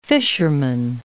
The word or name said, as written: fisherman